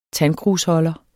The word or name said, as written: tandkrusholder